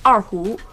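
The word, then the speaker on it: Arhu (二胡): In Mandarin, the pronunciation is Arhu.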